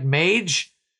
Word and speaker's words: Mage: I mean, I was going to take, if I think I'm saying it right, Mage. – Yeah.